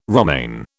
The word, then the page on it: romaine: British English